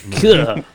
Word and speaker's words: Keough: Keough? How do you say that? – I don't know. – K-E-O-G-H. – Keough.